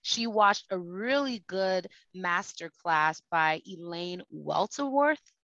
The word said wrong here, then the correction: Welteroth: Welteroth. – Mm-hmm. – That's how you pronounce it? – Yeah.